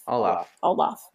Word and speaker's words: Olaf: I'm guessing that it's Olaf. – Olaf.